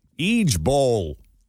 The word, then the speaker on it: IJBOL: Because instead of saying each letter, IJBOL, you're supposed to say each bowl.